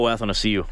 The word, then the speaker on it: Athanasiou: That other guy that everyone talked, how do we say his last name? Athanasiou? – Oh, Athanasiou.